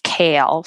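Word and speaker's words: Cale: And her name is Kale, spelled with a C, C-A-L-E, but pronounced like the leafy green.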